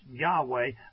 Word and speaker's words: Yahweh: So Yahweh, pronounced Yahweh, by the overwhelming majority of language experts that we can see,